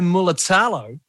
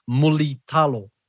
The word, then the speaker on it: Mulitalo: So his surname is Mulitalo. – Mulitalo. – Mulitalo. – Muli sounds like woolly, basically. – Mulitalo. Mulitalo. Ronaldo Mulitalo.